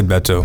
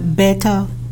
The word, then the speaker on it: Beto: Is it Beto or Beto? I say Beto.